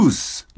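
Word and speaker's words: loose: 武田さんはルーズと言っておられましたが、正しくはルースです。 – え？もう一回お願いします。ルース。 – ルースとルーズ。 – 濁らないんだって。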